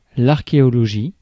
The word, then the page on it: archéologie: /aʁ.ke.ɔ.lɔ.ʒi/